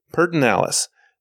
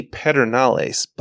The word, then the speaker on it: Pedernales: So, Pedernales. The way it's spelled, you'd think it'd be Pedernales, but unless you want to look like an out-of-town lamewad, it's Pedernales.